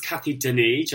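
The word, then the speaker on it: Cathy Dennis: And by the way, it's pronounced Kathy Denis, just like Glyphosate.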